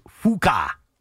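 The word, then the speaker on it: Fučka: There was a guy who played in the Olympics in 2000, and his name was Gregor, and his last name was Fuka. How do you spell that? – F-U-C-K-A.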